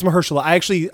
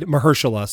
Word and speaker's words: Mahershala: It's Mahershala. I used to say... – I thought it was Mahershala. – That's what I used to say. But then I heard Kevin Feige, who another hard name to pronounce, right, yeah, said Mahershala. So I'm going to go with that.